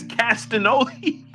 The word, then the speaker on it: Castagnoli: Yeah, they had Claudio Castiglione. Why do you laugh every time I say his name? You say it wrong. – Am I really? – Yeah, it's Castagnoli. – Oh, it's Castagnoli.